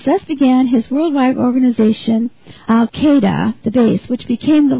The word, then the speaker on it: Al-Qaeda: It's pronounced Al-Qaeda. It's pronounced a different way than it's spelled, but maybe not. It's a capital A-L, and then there's a space, and then Q-A-E-D-A.